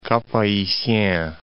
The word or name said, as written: Cap-Haitien